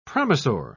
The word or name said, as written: promisor